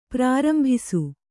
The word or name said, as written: ಪ್ರಾರಂಭಿಸು